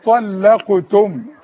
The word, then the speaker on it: طلقتم: وإذا طلقتم النساء، وقبل ما تنطق كلمة طلقتم ديت اللام مرققة، اللام بين الطاء التخينة والقاف التخينة، وهي زي الغزالة. وإذا طلقتم، فضل.